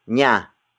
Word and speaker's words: niya: Aso is spelled as A-S-O. It means dog, the focus of this sentence. And then it is followed by nya. Nya. Nya. Nya is spelled as N-I-Y-A.